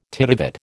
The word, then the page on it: titivate: American English